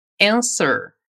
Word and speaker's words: answer: Mas a verdade é que você pode simplesmente ignorar que ele existe e pronunciar como se ele não estivesse ali, lembrando de caprichar naquele R do seu primo do interior. Vamos lá. Answer.